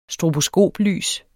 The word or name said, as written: stroboskoplys